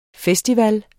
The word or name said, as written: festival